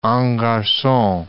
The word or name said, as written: en garcon